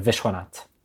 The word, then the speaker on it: Vishwanath: We usually pronounce it by taking the H at the end and putting it after the S. So Vishwanath. – Arun and Mina Vishwanath. Is that correct? – Yep. – Is it? Go on, you can be honest. – Yeah. You have to take away the H at the end. – So, oh, Vishwanath. – But an accent on the first syllable.